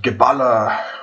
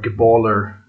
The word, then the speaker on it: Geballa: It's called Geballa, or Geballer, as the Americans pronounce it.